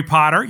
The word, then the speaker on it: Potter: My name is Jerry Potter. Yes, it rhymes with a boy wizard.